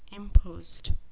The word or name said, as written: impost